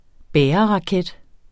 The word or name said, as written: bæreraket